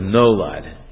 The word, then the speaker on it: nolad: And reason B of this is it's a form of, the word is nolad. In English it's spelled N-O-L-A-D. N-O-L-A-D.